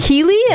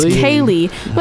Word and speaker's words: Keyli: Like K-E-Y-L-I. And I was like, is that Keely? And no, it's Kaylee.